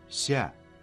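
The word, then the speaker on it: Ся (下): Ся, низкий, под чем-то, при чем-то, спускаться. На самом деле у этого слова много значений, и оно произносится в четвертом тоне.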